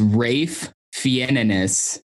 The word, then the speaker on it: Ralph Fiennes: Have you ever heard him pronounce his own name, Ralph Fiennes? – No. – Is it not Fiennes? – It's Rafe Fiennes.